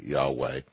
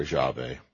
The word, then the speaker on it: Yahweh: And because of this, the Jews refused even to pronounce it, and its very pronunciation for that reason is now uncertain, although it is normally pronounced by scholars today, Yahweh or Yahweh, depending on how you take the Yod and the Vav and pronounce them in Hebrew.